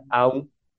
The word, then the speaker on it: Om: Se escribe Om, pero se pronuncia Aum.